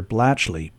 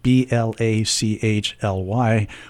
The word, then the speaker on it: Blachly: and my legal name on Facebook is Peter Blachly, B L A C H L Y, which nobody seems to be able to pronounce, which is why I use Alexander.